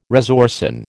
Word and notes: resorcin: American English

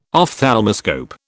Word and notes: ophthalmoscope: British English

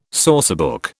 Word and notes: sourcebook: British English

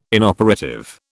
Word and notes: inoperative: British English

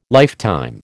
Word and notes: lifetime: American English

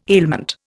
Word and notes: ailment: Indian

